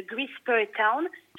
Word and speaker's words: Greasper Town: Il s'appelle euh, Mallory et le mystère de Grisper Town. Chers auditeurs, ne prononcez pas comme Dédé qui nous l'a rebaptisé Grippe ah euh, et c'est un roman que j'ai écrit en fait, les personnages, je les ai en tête depuis 2018.